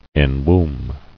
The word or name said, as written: Enwombed